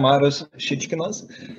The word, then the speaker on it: Marius Šičkinas: So I am Marius Šičkinas.